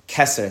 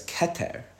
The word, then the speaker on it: Keter: So I could translate Kesser as Keter, if you're like more comfortable with that, but that's not really a translation, right? I'm just pronouncing it differently, mm-hmm. – right? I'd also say Kether, if you like want to, if you really insist on the soft THs in all of your suffs, you know, like the Sabbath thing, but it's not a translation.